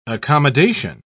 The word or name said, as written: accommodation